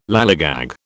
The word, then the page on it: lallygag: British English